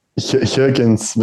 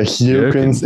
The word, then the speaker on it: Geukens: Geukens, but yeah, Geukens. In English, okay. It's hard to pronounce. Yeah, Geukens.